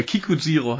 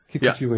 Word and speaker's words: Kikujiro: Ähm, Kikiyo, Kikiryo Jiro Sommer. Ja, jetzt hat's... Japanische Namen. Fast. Ja. Fast, fast, ja. Ja. Das verdammte U. ja, genau. Äh, Kikujiro heißt er. Ja, Kikujiro, ja, ja genau.